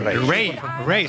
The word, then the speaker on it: Raye: Ray, se escribe Raye, Raye con Raye, ella.